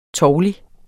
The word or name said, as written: tovlig